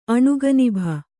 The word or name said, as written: ಅಣುಗನಿಭ